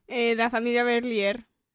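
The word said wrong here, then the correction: Bélier: La familia Belier. – Bueno, ¿cómo se dice? – Correcto, sí, bueno, Belier, Berlier, Belier.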